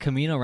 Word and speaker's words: Camino: Wait, Camino rhymes with Tamino.